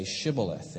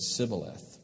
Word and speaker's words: Shibboleth: When he said, No, they said to him, Then say Shibboleth. And he said, Sibboleth for he could not pronounce it right.